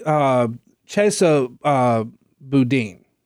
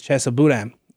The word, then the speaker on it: Boudin: Take Chesa Boudin, I think is how he pronounces it.